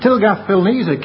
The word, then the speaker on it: Tiglath-Pileser: Tilgath-Pileser, king, I don't think I pronounced it like that a couple of minutes ago, never mind. Tilgath-Pileser, king of Assyria, came on to Ahaz and distressed him.